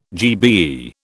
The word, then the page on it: GBE: British English